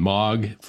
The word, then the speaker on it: M3AAWG: And then MOG, for people who aren't familiar, that's spelled M3AAWG, the Messaging Malware and Mobile Anti-Abuse Working Group.